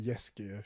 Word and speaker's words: Jaskier: Oh, wait, I think it is because my girl says it as Jaskier. That's how you say it.